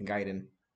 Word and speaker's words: Gaiden: So track number two, Christmas Gaiden, if I said that right. – Gaiden.